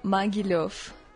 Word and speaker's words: Mogilev: Como se fala o nome dessa cidade? – Fala Mogilev.